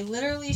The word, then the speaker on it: literally: Jen normally adds a T in the word literally when we first really started becoming friends.